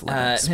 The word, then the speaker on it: Linux: You can get it on Windows, Mac, Linux, PlayStation 4. I don't know what Linux is, but that's what it says. – It's Linux, but that's okay.